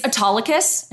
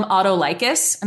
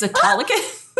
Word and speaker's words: Autolycus: So I remember I was like, it's Autolycus, and she would call him Autolycus. And I was like, you're saying that wrong. It's Autolycus.